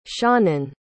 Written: seanan

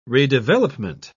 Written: redevelopment

